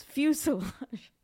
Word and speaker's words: fuselage: So three syllables, fuselage.